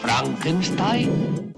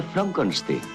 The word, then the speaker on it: Frankenstein: ¿Doctor Frankenstein? – Frankenstein. Se pronuncia Frankenstein.